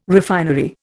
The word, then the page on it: refinery: Indian